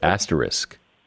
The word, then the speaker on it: asterisk: Asterisk. I'm sorry, asterisk. – Okay, well, so two things just happened there. At first, you said asterisk without an S, and then you said asterisk with an S.